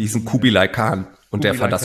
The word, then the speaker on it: Kublai Khan: Und das sind Kublai Khan, richtig ausgesprochen? – Kublai Khan, TX.